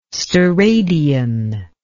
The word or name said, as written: steradian